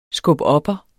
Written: skubopper

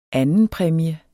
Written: andenpræmie

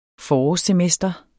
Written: forårssemester

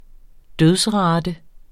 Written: dødsrate

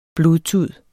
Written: blodtud

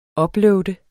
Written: upload

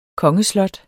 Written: kongeslot

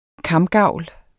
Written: kamgavl

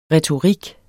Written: retorik

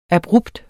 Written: abrupt

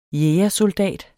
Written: jægersoldat

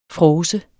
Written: fråser

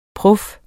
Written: prof

